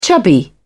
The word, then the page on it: chubby: British English